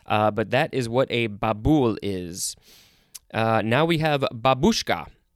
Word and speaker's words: Babul: Okay, now we have Babul, B-A-B-U-L. The emphasis is on the second syllable.